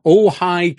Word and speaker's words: Ojai: They're from Ojai, California. – Right. – How is Ojai spelled? O-J-A-I. That means J-A-I is pronounced hi. What is Jamie Summers' first name? And how is it spelled? It's spelled J-A-I-M-E.